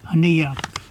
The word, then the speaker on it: Aniak: How do you say that one? Aniak? – Aniak.